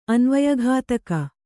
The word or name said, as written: ಅನ್ವಯಘಾತಕಿ